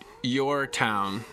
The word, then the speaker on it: Y/Our Town: It's actually I pronounce it Your Town, and it is a. That basically started, it's spelled Y/Our Town, and the reason I named it that because it was a project I started on the last Shushu tour, the last U.S.